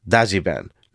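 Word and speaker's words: Dajiban (ダッジバン): And these Dodge vans in Japan are known as Dajibans, because in Japan, you know, with people whose first language is Japanese, the two words Dodge van is actually pronounced Dajiban, because their heavy accents have basically created a new word that Dodge vans are known by in Japan.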